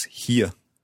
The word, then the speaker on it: hier: It sounds very similar. It's here. – Here. Okay, very similar indeed. So can we just put that in the same word order? I live here in Scotland.